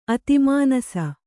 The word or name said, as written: ಅತಿಮಾನಸ